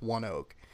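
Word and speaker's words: ONEOK: All one word, but it's pronounced One Oak.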